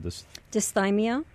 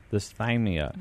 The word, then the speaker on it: Dysthymia: Dysthymia. – Dysthymia.